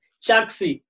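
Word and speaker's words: Chaxy: This has been Chaksi without the C. Niliambua hivyo leo. Bro. – Uleka simbili. That's C-H-A-X-Y.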